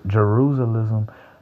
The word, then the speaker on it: Jerusalem: You know what they say when when people try to come after the place, not Jerusalem, but damn, I said that wrong. Jerusalem. I'm sorry, Father." Jerusalem.